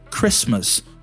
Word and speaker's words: Christmas: One thing about pronunciation, the T in the word Christmas is not pronounced. So we don't say Christmas. We just say Christmas, Christmas.